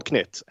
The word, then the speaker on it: Knut: I mean, Knut, which, by the way, is how you pronounce it. Knut, it's a Swedish word meaning basically a tiny creature.